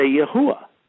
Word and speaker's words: Yahuwah: So all you do to figure out how to say his name is knock out the Dalad and just say Yahuwah.